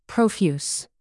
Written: profuse

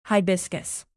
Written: hibiscus

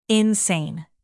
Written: insane

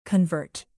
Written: convert

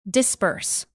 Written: disburse

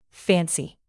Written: fancy

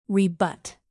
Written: rebut

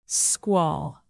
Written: squall

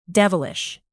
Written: devilish

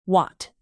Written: watt

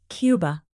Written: Cuba

